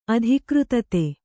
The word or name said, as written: ಅಧಿಕೃತತೆ